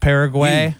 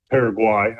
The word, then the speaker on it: Paraguay: Can I lean on you to tell me if USA is going to be looking good against Paraguay? – USA. USA. – They've struggled against Paraguay, I believe is the way it's pronounced.